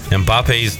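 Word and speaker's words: Mbappé: The top headline on ESPN, Mbappe's dilemma.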